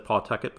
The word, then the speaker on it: Pawtucket: He earns a call up to AAA Pawtucket, which I recently learned is the proper way to pronounce that. I think I had said Pawtucket before. Pawtucket. And listening to Mike pronounce it, that's how he pronounced it.